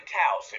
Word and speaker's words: Taos: So you are the pastor of Teos First Baptist Church. Am I pronouncing that right, by the way? – It's actually Taos. It rhymes with house.